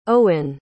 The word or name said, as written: owyn